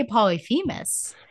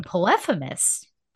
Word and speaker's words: Polyphemus: I say Astyanax, but someone was saying something I can't even know, but I say Polyphemus. And so you're saying Polyphemus. – I've used both.